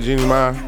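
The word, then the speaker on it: Jeannie Mai: Jeezy and Jeannie Mae, that's how you say it, Jeannie Mae, they broke up.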